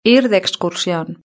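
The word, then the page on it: ir de excursion: Spain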